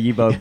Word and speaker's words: Yebo: A lot of people say Yebo, but it's actually pronounced Yebo, which means Yebo. Yes in Zulu.